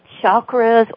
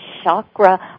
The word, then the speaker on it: chakra: And then there are those places called chakras, or some people pronounce them chakra. I like chakra because it's more like chocolate.